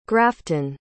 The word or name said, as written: grafton